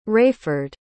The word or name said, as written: Rayford